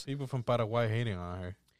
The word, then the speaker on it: Paraguay: How do you pronounce it? – Paraguay.